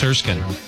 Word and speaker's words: Erskine: It looks like Erskine, but it's Erskine. Just spelled odd.